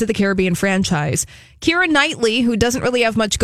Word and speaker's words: Knightley: That's tough, yeah, because it's the silent K. – We never would have got that, Knightley.